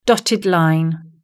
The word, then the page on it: dotted line: British English